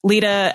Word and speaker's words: Leda: But Lita, L-E-D-A, the Greek way.